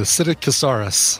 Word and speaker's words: Psittacosaurus: You just don't pronounce the P. The Psittacosaurus.